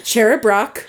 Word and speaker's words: Cherub Rock: How do you spell that? – Cherub. – Cherub. – Oh, Cherub nope. Rock. – You said it so quickly multiple times. I heard Cherub Bra, and then I heard Cherub Rock.